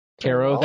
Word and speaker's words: Carroll: Carroll? – That's not the... Yeah. – I say Caro for some reason. – Rhymes with marrow.